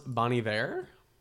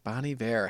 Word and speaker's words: Bon Iver: Is it Bon Iver? How do you say it? You love him. – I think it's pronounced Bon Iver. – Bon Iver.